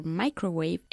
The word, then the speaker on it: microwave: Karen uses her microwave every day. – Good. Lo de micro se pronuncia micro en inglés, ¿eh? Microwave, microphone, micrófono, ¿ya?